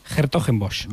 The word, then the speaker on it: Hertogenbosch: Hertogenbosch.